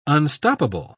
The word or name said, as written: unstoppable